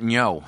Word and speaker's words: Ngo: I hope I'm pronouncing his name right. N-G-O, Ngo.